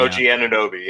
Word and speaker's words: Anunoby: Help me OG Anunoby, yeah.